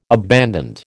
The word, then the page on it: abandoned: American English